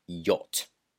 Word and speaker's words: yacht: Notice the spelling, Y-A-C-H-T, but the pronunciation, yacht.